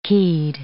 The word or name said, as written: keyed